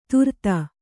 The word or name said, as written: ಧೂರ್ತ